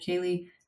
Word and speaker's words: Kaileigh: My name is spelled very odd. Thank you to my parents. It is not your standard Kaylee. Nobody at Starbucks can ever spell it correctly. But for what it's worth, K-A-I-L-E-I-G-H. Don't forget the first I.